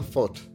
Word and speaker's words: Vogt: I know if you've seen the spelling of his name, V-O-G-T, you may think I'm hopelessly mispronouncing it, but he informed me that in his native Norwegian, the V is pronounced like an F.